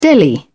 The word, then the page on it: deli: British English